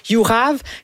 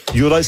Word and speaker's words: Juraj: Personnellement, moi, j'ai de la difficulté avec Jurav Slavkovski. – Juraj.